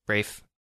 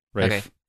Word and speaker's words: Ralph: I think he pronounces it Ralph Fiennes. – Okay. Rafe. – Rafe. Okay.